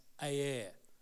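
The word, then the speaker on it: Er: Judah has three boys, and he marries his first son called, it's spelled E-R, but it's pronounced Aer.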